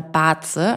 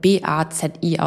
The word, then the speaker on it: Bazi: Und wenn ich mir unterschiedliche Systeme anschaue, wie Astrologie oder Baze, also ich weiß nicht, ob du Baze kennst, es wird Bazi ausgesprochen.